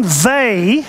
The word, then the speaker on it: Weil: In your outline are what Simone Weil, that's how you pronounce it, it's a French lady, she calls these the marks of suffering.